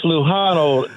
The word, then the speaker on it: Lujano: It's Lujano. The J is an H like in San Jose.